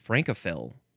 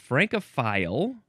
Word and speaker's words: Francophil: I guess that one would be pronounced Francophil. So it's Francophile or Francophil, adjective from 1887.